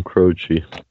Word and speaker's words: Croce: Crocky or Croce? How you say his name? – Yeah, Jim Croce.